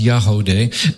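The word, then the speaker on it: Yagody: Yahodeh is spelled for the audience. It's Y-A-G-O-D-Y, but it's pronounced Yahodeh.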